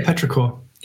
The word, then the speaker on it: Petrichor: The 1975, you picked How to Draw / and I think I'm going to mispronounce this, Petrichor? – Yeah, Petrichor.